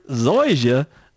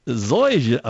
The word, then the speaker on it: Zoysia: How do I say that word? Zoysia. Zoysia. Okay, I got it now. Zoysia.